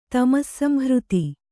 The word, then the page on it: ತಮಸ್ಸಂಹೃತಿ: tamassamhřti